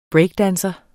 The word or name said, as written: breakdanser